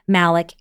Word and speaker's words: Malik: s-t-e-p-h-y-n-i-e malik m-a-l-i-k um, dot com and then forward slash elevated that's awesome.